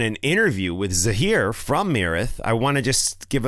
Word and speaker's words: Myrath: The band, M-Y-R-A-T-H, Myrath. Myrath. It looks like Myrath, but I believe they say Myrath.